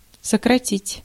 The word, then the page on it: abbreviate: [ə.ˈbri.vi.ˌeɪt]; /əˈbri.vi.eɪt/